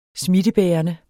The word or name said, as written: smittebærende